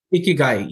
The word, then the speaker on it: Ikigai: but is it I- Ikigai? Is that right? – Ikigai, yes.